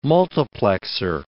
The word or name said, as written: multiplexor